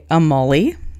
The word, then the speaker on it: Amuly: So the first fic is called Parker's Puppy Paradise by Amuly, which is like, sounds like Emily, but it's A-M-U-L-Y. Assume it's pronounced the same way.